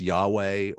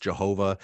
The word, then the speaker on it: YHWH: which is YHWH, sometimes pronounced Yahweh, or has been mispronounced Jehovah. Some scholars are starting to believe that name is actually best pronounced. The YH is breathing in and WH is breathing out.